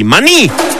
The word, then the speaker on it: Maní: Maní, perdón, Maní. Acento en la I. Maní.